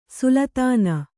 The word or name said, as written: ಸುಲ್ತಾನ್